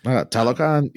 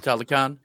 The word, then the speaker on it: Talokan: I want Dr. Doom to show up at Lakatan. Is that how you say it? – Talokan? Yeah. – Talokan.